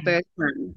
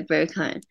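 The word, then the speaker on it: Berghain: 哦，对，我想要先请你分享一下啦，当时会决定突然要飞去柏林的那个契机，就是我们上次有聊到那个很有名的夜店，那个怎么念啊？Birkin。 – Berkheim，对 Berkheim，对，你可以分享一下你那个经验吗？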